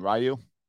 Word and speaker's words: Ryu: Ryu? – Yes. – Hyunjin Ryu. I'm sure Ryu.